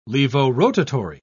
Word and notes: levorotatory: British English

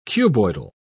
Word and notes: cuboidal: British English

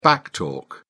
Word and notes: back talk: British English

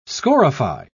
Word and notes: scorify: British English

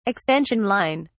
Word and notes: extension line: British English

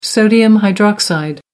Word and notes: sodium hydroxide: American English

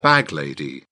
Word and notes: bag lady: British English

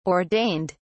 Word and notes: ordained: British English

